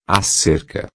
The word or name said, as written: cerca